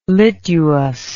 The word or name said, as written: lituus